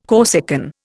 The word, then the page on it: Corsican: Indian